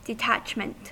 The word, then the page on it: detachment: American English